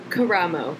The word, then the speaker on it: Caramo: And uh, is it Carambo? Is that how you say his name? – Caramo. – Caramo.